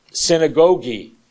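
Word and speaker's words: synagōgē: The Greek word for assembly sounds like synagogue. It is pronounced synagogi.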